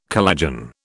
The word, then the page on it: collagen: British English